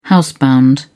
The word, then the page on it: housebound: British English